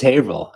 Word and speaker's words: Haverhill: People from Haverhill, they don't mess around. So like, yeah. – No. – It's Haverhill, and you get one mulligan on that. You better not say it wrong twice.